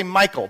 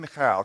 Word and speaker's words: Michal: Now the Hebrew pronunciation of that M-I-C-H-A-L is Michal. Some people say Michael, but then it sounds like David married a dude.